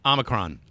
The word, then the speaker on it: Omicron: Brazil and Japan join the rapidly widening circle of countries to report cases of Omicron. How do you say it? – Omicron.